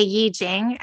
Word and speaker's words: I Ching: Just to recap, the I Ching, it's spelled I Ching, but we say Yi Jing is the proper pronunciation.